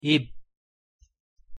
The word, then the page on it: 입: Korean